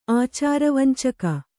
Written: ಆಚಾರವಂಚಕಿ

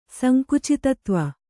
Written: ಸಂಕುಚಿತತ್ವ

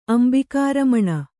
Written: ಅಂಬಿಕಾರಮಣ

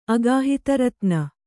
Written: ಅಗಾಹಿತರತ್ನ

